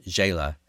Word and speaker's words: Zsela: And then I want to mention a new EP called Ache of Victory from the singer-songwriter Jayla. She's from New York City, a stunning voice. Fans have been waiting a long time for this one. Ache of Victory from Jayla. And that's spelled Z-S-E-L-A.